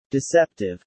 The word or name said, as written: deceptive